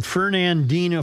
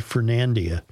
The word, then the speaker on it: Fernandina: Only because they come to us all the way from Fernandina, Florida, and I was wondering why in God's name was I pronouncing that Fernandia. – Fernando? – Because I just didn't look at it closely enough.